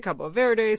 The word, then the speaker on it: Cabo Verde: what people there would say, Cabo Verde, same country.